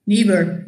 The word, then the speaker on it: Niebuhr: So it's actually pronounced Niebuhr. – Niebuhr, yes.